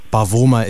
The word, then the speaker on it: Bavuma: I nearly was going to be the man who thought he was schooling people, but I was going to say, Bavuma is on the line. And then I had to be schooled by my mother, who said, no, Maka, Bavuma is how you pronounce the man's name.